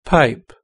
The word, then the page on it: pipe: British English